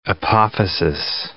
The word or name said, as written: apophasis